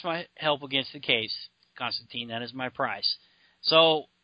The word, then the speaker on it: Constantine: Then you got the little scene here with Constantine and I said it right that time? – I never know. – Constantine? – Yeah.